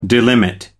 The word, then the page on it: delimit: American English